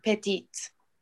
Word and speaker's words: petit: Les lits sont petites. – Petites. So the T is silent.